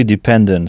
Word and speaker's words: dependent: Donc, en début de mot, on entend « di », qui s'écrit pourtant « de », « dependent », avec « ent » à la fin, et non « ant », comme en français.